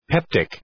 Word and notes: peptic: ‘peptık

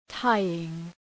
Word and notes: tying: ‘taııŋ

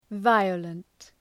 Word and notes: violent: ‘vaıələnt

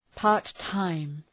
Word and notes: part time: {,pɑ:rt’taım}

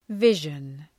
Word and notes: vision: ‘vıʒən

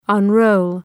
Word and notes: unroll: ʌn’rəʋl

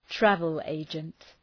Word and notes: travel agent: ‘trævəl,eıdʒənt

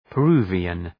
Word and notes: Peruvian: {pə’ru:vıən}

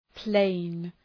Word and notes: plain: pleın

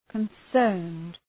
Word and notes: concerned: {kən’sɜ:rnd}

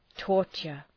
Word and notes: torture: ‘tɔ:rtʃər

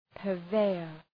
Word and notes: purveyor: pɜ:r’veıər

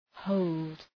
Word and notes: hold: həʋld

